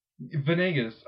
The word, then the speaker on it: Venegas: Venegas. I get Vegas like more often than I want.